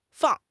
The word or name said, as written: fang4